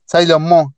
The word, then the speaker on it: Sailor Moon: Sailor Moon Moon, perdón.